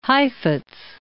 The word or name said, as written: Heifetz